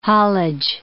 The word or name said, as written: haulage